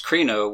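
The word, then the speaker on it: krino (κρίνω): The Greek word 29:19 for judge here is pronounced krino,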